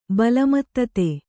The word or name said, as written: ಬಲಮತ್ತತೆ